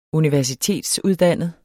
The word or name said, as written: universitetsuddannet